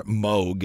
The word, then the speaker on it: Moog: If you bought a synthesizer, there's a real good chance you bought either a Moog, or Moog is actually how it's pronounced.